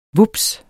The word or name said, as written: vups